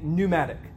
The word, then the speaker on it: pneumatic: But the sacraments are, I think we could say, mystical and pneumatic. P-N-E-U-M-A-T-I-C. It rhymes with automatic, but it's spirit wrought, okay?